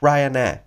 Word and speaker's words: Ryanair: Yo viajaba desde Sevilla hasta el aeropuerto de Luton, aquí en Londres, con eh, la compañía Ryanair, ¿de acuerdo? O Ryanair, si lo queréis pronunciar en inglés, perfecto.